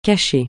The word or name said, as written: cacher